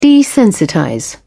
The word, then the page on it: desensitize: British English